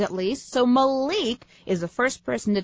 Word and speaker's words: Malik: Yes, M-A-L-I-K. Often these days with names of people that are from the black culture, the I is pronounced like an E. So this name is Malik, not Malik.